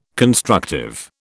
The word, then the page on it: constructive: British English